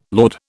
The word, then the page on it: laud: British English